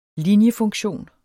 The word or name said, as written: linjefunktion